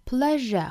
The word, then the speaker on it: pleasure: Such pleasure this, 这个 pleasure, 我们注意中间的这个 s 的发音呢，是要把它发成一个 r 的发音啊，是一个浊辅音，不要把它发成了这个清辅音 sh, 应该是 r, pleasure,